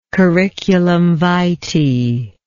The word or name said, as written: curriculum vitae